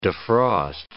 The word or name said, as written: defrost